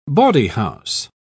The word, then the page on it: bawdyhouse: British English